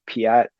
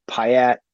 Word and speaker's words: Pyatt: ambassador to Kiev at the time, Jeffrey Pyatt, Pyatt, it's spelled P-Y-A-T-T.